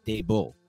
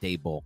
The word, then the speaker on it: Daboll: Today, we're here to break down the early combine interviews from Giants GM Joe Schoen and from Giants head coach Brian Daboll. And I got to remind myself every time that it's Daboll, not Daboll, because I keep thinking to default to Daboll.